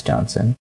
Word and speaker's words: Johnson: Yes, it's Alex Johnson.